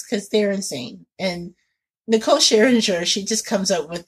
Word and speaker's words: Scherzinger: It's Jenny McCarthy, Nicole Scherzinger? I can't. – Scherzinger. – Scherzinger.